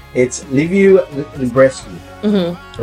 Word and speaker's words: Liviu Librescu: It's a Romanian name, so it's not just Hebrew, which I have a fine pronunciation of Hebrew, but it's Liviu Librescu, right?